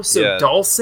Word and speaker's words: Dulce: Are we saying Dulce or Dulce? We need to know now. – I say Dulce. – So it's Dulce, New Mexico. Dulce is the correct pronunciation.